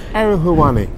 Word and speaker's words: Ariel Helwani: Ariel Helwani, I'm saying his name wrong because I mess up everyone's name. – Yeah, Ariel Helwani.